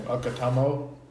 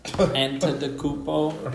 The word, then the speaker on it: Antetokounmpo: Okutamo. – Yeah. I just go like this when I say his last name. – Antetokounmpo.